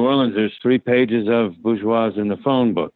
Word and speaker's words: Bourgeois: Bourgeois? – Yeah.